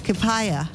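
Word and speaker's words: Kipya: Kipaya. Kipaya. Kipaya. Pretty much spelt exactly how it sounds. Kipaya. It means to close it. It's spelled K-I-P-Y-A. K-I-P-Y-A.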